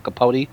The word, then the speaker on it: Capote: when I was talking about Capote, that it does not fucking rhyme with Chipotle.